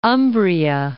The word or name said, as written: Umbria